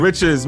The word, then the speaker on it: Moriches: Moriches. Remember, Moriches. The more riches, the more money.